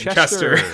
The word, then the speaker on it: Chester: Chester. – Rhymes with Jester.